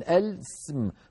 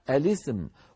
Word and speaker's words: الاسم: اسم هذه الكلمة أول همزة وصل، لو دخلت عليها لام التعريف فقلنا اللام ساكن السم، فالهمزة تسقط، همزة الوصل في كلمة اسم تسقط، فيلتقي في النطق ساكنان. ما هما الساكنان؟ لام التعريف والسين من كلمة اسم، السم. والعرب لا تجمع بين ساكنين، فيكسرون لام التعريف، يكسرونها يعني يحركونها بالكسر للتخلص من التقاء الساكنين، فيقولون الاسم، وهو ما نقرأه في سورة الحجرات: بئس الاسم الفسوق بعد الإيمان.